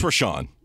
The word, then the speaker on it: Richaun: It would be interesting if Rishon is pronounced Rishon, given that it starts Rich. R-I-C-H-A-U-N. All right. So this guy. – It's Rishon.